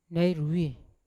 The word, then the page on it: nairoi: Yawa